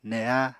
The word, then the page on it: neha: Apatani